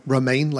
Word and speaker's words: Romijn: Romijn, like romaine lettuce? – Like the lettuce.